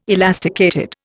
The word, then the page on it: elasticated: Indian